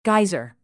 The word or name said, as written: geyser